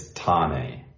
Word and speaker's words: Thane: There's been an international partnership announcement with Thane City FC, our first partnership in India. – Yeah, and I'm going to correct your pronunciation there, so we'll probably end up doing quite a lot. It's Thane.